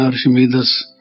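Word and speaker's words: Archimedes: अर्शमेदीज, कहीं उसको प्रोनाउंस करते हैं अर्शमेदस,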